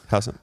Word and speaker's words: Houghton: You know, they Israel Houghton, is that how you say it? – Yeah. – Houghton, yeah.